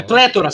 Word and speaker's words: pletora: P-L-E-T-O-R-A. É pletora. – Na realidade não é pletora, é pletora a pronúncia correta.